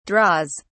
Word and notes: draws: British English

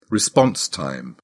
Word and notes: response time: British English

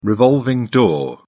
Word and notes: revolving door: British English

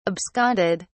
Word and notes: absconded: British English

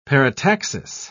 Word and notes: parataxis: British English

